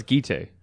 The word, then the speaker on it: Gitte: I thought it's Gitte. Accent on the first syllable.